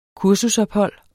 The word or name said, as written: kursusophold